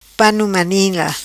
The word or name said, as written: Pano Manila